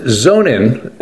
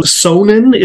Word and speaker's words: Sonnen: So, just to kind of start off with, I noticed that you pronounce it zonin, and so it sort of sounds like zone, like the end zone or something like that, and I guess that might be the German pronunciation.